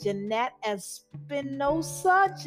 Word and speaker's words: Jeanette Espinoza: I'm here with Jeanette Espinoza. Jeanette, I don't want to butcher your name. Could you let the people know the exact pronunciation of it, please? – No, you got it perfect.